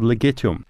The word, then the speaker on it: Legitum: De Muller is the name of the family that owns the property, and it's called Legitum. And it sounds like the word legitimate, which it was.